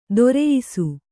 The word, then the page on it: ದೊರೆಯಿಸು: doreyisu